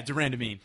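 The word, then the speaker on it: de Randamie: But you have Anna the Panda Elmos fighting Jermaine the Iron Lady Durandamy. Did I say that right? – Yeah, Durandamy.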